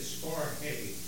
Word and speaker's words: storge: But the first was a word called storge, or stork. I can't even say it, storge. It's almost like the Spanish with a soft G.